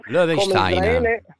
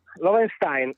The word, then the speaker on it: Loewenstein: non so se pronuncio correttamente l'autore, Anthony Lowenstein. – Lowenstein. – Lowenstein, ecco, grazie per la correzione, grazie mille.